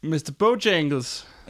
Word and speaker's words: Mr. Bojangles: Mr. Bojangles.